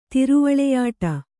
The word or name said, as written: ತಿರುವಳೆಯಾಟ